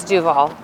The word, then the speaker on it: Duval: How do we say it? – Duval. – It's Duval. – Duval. – And how does Duval. Ben say? – He says Duval. – Like yeah. Robert Duval. Duval.